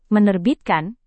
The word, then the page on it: menerbitkan: Indonesian